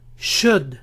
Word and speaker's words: should: Should, should. No pronuncien la L. Should.